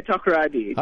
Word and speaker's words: Takoradi: You come from a place in western Ghana called, now you got to help me say this location correctly. It's Takoradi? – Yeah, Takoradi. Takoradi.